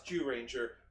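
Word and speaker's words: Zyuranger: Yeah, any jokes unless it says Z on the buckle. It's Zoo Ranger. – It says Z. – It's pronounced Jew Ranger.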